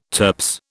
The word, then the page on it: turps: British English